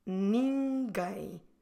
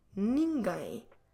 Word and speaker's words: ninguém: Ninguém. Again, don't close your lips when you finish to pronounce this word. Keep them open. Nin, very nasal, ni, and then gai, again nasal. Ninguém. Ninguém. Como se escreve ninguém? N-I-N-G-U.